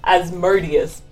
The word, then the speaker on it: Asmodeus: Yeah, there was, Anya pronounces it Asmodeus because... – Both are acceptable. – Asmodeus is just going to make me think about Amadeus the entire time. – Asmodeus.